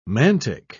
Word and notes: mantic: British English

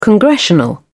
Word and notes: congressional: British English

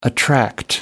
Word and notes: attract: American English

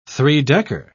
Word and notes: three-decker: British English

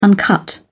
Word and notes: uncut: British English

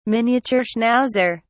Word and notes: miniature schnauzer: British English

